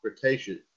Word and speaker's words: Cretaceous: DreamWorks Animation and Netflix officially announced today, announced this yesterday, the new upcoming animated series, Jurassic World Camp Cretaceous.